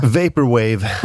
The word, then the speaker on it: Vaporwave: Vaporware, om jag får be. – Vaporwave. – Vaporwave, flowing. – Jag kan inte uttala det. Vaporwave. – Vaporwave, ja, precis.